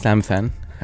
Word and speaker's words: Amiththan: My name is Amiththan, and it's a pretty hard one for most people because the way it's spelled.